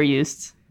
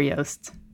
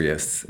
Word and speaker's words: Joost: I realize I've always been pronouncing it as Super Joost. It was actually Super Joost. – It's Super Joost.